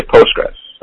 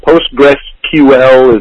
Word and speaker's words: Postgres: I just say Postgres. PostgreSQL is a little too much for me. So Postgres, PostgreSQL. – Yeah, well, it could be. But the thing, you know what? I don't like pronouncing it SQL because SQL is like a Microsoft thing.